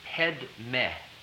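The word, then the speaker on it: Padme: pronunciation is Tibetan in this case, even though the spelling would be spelled as if you were pronouncing it in the Sanskrit. So it's Om Manne, Manne with the back of the nose closed, Manne, it's against the roof of the mouth, Manne, Manne, Pedme, Pedme, Pedme, Hung, as if it were spelled H-N-U-N-G, Hung, Hung.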